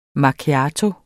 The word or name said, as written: MACCHIATO